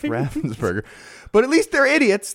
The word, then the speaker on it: Raffensperger: and Raffensperger, which rhymes with all the other burger stuff, so that's confusing too.